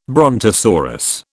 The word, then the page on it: brontosaurus: British English